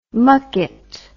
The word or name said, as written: mucket